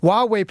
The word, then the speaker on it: Huawei: Huawei P50 will have Google installed.